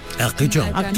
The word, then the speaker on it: Arcachon: Arcachon, ¿cómo se dice eso en francés? – Arcachon. Tú le pones así un poquito la voz y ya parece francés, digas lo que digas.